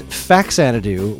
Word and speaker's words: Faxanadu: Uh, the first one I played was Faxanadu, which is actually pronounced Fa Xanadu because it's the Famicom version of Xanadu, which itself is a spin off of Dragon Slayer.